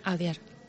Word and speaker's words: Audiard: Y concretamente, las películas que has elegido tú, son Un profeta y de Jacques Audiard, no sé si lo he dicho bien. – Audiard.